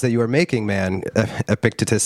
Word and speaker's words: Epictetus: Epictetus. – Epictetus.